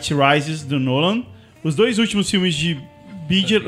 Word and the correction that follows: Bigelow: eu não sei pronunciar isso. Da Bigelow. Bigelow.